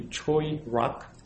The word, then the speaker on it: Choirock: It's a Korean company the, the correct pronunciation would be Choirock. Choi Rock, thank you.